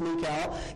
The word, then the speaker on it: Michal: I know we've probably all said Michael in all of our reading of it, but the Hebrew pronunciation is Michal, gets wind of it, and she, like her brother Jonathan, seeks to protect David.